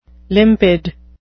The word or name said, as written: limpid